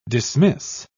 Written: dismiss

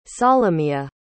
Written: solomiya

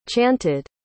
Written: chanted

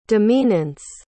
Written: demeanance

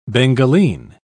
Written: bengaline

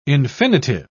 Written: infinitive

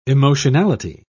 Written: emotionality

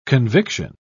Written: conviction

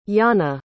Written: janne

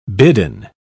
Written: bidden

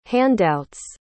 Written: handouts

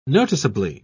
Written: noticeably

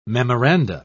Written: memoranda

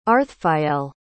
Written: arthfael

